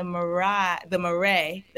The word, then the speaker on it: Marais: Oh, I'm in the Marais. That's how you say it in France.